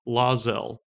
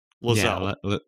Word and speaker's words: Lae'zel: L-A-E apostrophe Z-E-L. – Z-E-L. Lazelle. – Lazelle. Lazelle. – Lazelle. Yeah. Okay. – That's what I say. Lazelle.